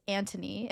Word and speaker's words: Anthony: It's spelled Anthony, but it's pronounced Antony.